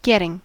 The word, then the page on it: getting: American English